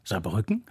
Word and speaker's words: Saarbrücken: Aber den Ort Saarbrücken spricht man mit der Betonung auf der mittleren Silbe, Saarbrücken.